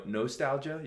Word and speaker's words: nostalgia: No real great stories that there, but lots of, you always say nostalgia. You kind of go with the long O, so I'll say nostalgia. Or not. Yeah. But I love the way you say nostalgia, so don't change.